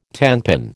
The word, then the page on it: tenpin: American English